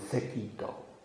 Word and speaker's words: Sekito: We often pronounce, they're Chinese monks, but we pronounce them in Japanese because of the Japanese Zen tradition. Seigen and Sekito.